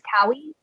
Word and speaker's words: Kaui: So it's Cynthia Kaui. So you can spell it C-Y-N-T-H-I-A. Then my last name is Kaui, K-A-U-I.